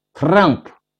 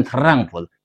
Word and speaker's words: Trump: Trump 是德国发音， Trump 特朗普，他是美国人，美国总统，美国发音，他不念 Trump，念 Trump，川普。